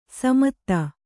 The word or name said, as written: ಸ್ಮಿತ